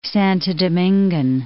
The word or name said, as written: Santo Domingan